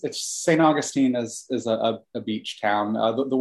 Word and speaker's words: Augustine: St. Augustine. Wow. What a great name. – Yeah, except as we know, it should be pronounced Augustine. – Yeah. – But the people here don't have the correct pronunciation.